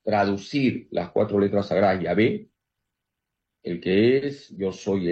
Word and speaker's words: Yahvé: Se pronuncia Yahvé. No se dice Jehová, hermanos. Se dice Yahvé.